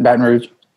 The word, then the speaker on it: Baton Rouge: What's the proper, correct way to say that, Baton Rouge? – That's right, Baton Rouge.